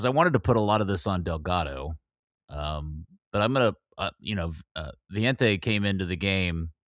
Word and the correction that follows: Valiente: Did we come to an agreement it's pronounced Vientte? – Valiente. – Valiente. Valiente? – Yeah. Means the brave, by the way. – Oh, okay. – Valiant, yeah. – Yeah, like valiant, kind of, yep.